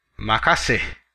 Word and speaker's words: makasih: In Indonesian, the informal way of expressing gratitude, like thanks in English, is makasih. Makasih. Let's break it down by syllable. Makasih.